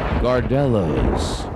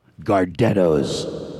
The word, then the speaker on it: Gardetto's: Gardetto's.